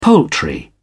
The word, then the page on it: poultry: British English